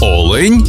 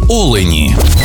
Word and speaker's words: олень: В однині і в множині наголос падає на перший склад – олень та олені.